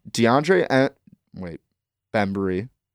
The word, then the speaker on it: DeAndre Bembry: I was going to do DeAndre Bembry.